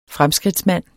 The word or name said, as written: fremskridtsmand